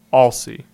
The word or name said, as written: Alsey